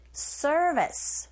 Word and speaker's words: service: 잡아주시고 서비스가 아니라 우리식 발음이 아니죠. R이 들어가 있기 때문에 service 그리고 B가 아니라 V인지 역시 긁어주시고 service.